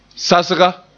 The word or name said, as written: sasga